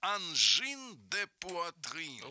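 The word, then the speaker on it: Angine de Poitrine: Владули, как профессиональному музыканту, интересно будет прослушать, нас наталкивают на находку, канадский ансамбль с непроизносимым французским названием, а это Тимурику непроизносимое, нам произносим, называется «Анжин де Пуатрин». – Вот так вот, Анжин... раз, два, три, умница.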